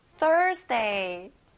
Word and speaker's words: Thursday: 앞에 Thur에 강조해 보세요. Thursday.